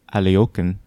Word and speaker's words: Alekhine: All right, A, Alekhine's gun, Alexander Alekhine, or... Alyokin, I think, is how you're supposed to pronounce it.